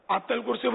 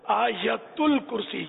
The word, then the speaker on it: آیت الکرسی: پڑھنا صحیح ہے, عوام اس کو بولتے یا تو بولتے آتل کرسی بولیں گے یا آیت الکرسی, اس کا صحیح تلفظ ہے آیت الکرسی, یا ہے آیت الکرسی. تو صحیح تلفظ ہونے چاہیے.